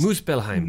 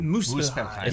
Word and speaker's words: Muspelheim: And just going through that and the way that ties into the other, like, Muspelheim. Sorry if I pronounce these. – Muspelheim. – Muspelheim. – Yes. Muspelheim.